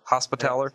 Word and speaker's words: Hospitaller: Is the cleric meant to be more close to a priest or more close to sort of a hospitalier? Is that how you say it? – Hospitaller. – Hospitaller. Yeah. Thank you.